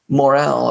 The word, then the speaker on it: morel: Like I remember the picture of the morel, or how do you say it? Moral or morel? The mushroom. Yeah. Which one do you say? – Morel.